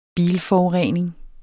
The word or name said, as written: bilforurening